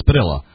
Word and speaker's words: Padilla: I know he's a name's allegedly pronounced Padilla, but I just can't bring myself to do that. – Well, it is Padilla.